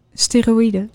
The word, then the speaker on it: steroïden: Hoe spreek je dat uit? Steroïden? – Steroïden.